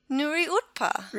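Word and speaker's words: Nuriootpa: So previously known as the Barossa Bush Gardens Parkrun is now going to be called Nuriutpa. – No, it's not. – Yeah, it is. – No, how is it? Go on, try again. – Nuriutpa. – Really? – That's how you pronounce it.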